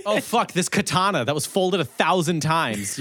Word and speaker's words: katana: Katana, and that's how it's pronounced. Katana. Yeah. – Katana.